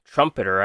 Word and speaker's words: Trumpeter: So the core of the deck, you got three Angel Trumpeteers. Trumpeter, I've been saying it wrong the whole time.